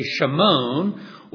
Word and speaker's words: Shimon: I remember reading something on that, and they said something like one out of every five Jewish men was named, well, we'd pronounce it today Shimon, or in New Testament days, we'd call Simon or Simeon.